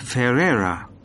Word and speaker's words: Ferreira: It's Ferreira. – How do you spell that? – It's F-E-R-R-E-I-R-A. – And what's your first name? – Jose. That's J-O-S-E.